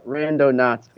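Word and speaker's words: Randonauts: It's Rondonauts because, you know, it's like it's a cosmonaut, kind of like that, like someone who explores the random.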